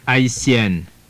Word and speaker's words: Haïtienne: Whereas with the accent, it means a Haitian woman and is pronounced Haïtienne. Haïtienne.